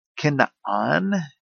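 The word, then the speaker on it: Kinaan (כנען): Kinaan, the Hebrew pronunciation,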